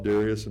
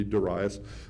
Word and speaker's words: Darius: I was always taught Darius, and now I know it's supposed to be Darius.